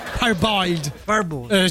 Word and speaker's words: parboiled: Il riso deve essere parabolled. Scritto paraboiled, scrivo bene, mi Paraboiled, si legge però? Parboiled. Giustamente che in inglese.